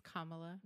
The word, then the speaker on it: Kamala: It's Kamala.